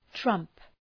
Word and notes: trump: trʌmp

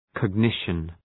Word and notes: cognition: kɒg’nıʃən

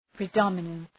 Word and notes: predominance: prı’dɒmənəns

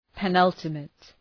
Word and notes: penultimate: pı’nʌltəmıt

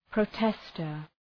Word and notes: protester: prəʋ’testər

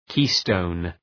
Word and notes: keystone: ‘ki:,stəʋn

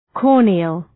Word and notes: corneal: ‘kɔ:rnıəl

